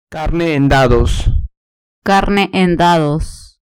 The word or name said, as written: carne en dados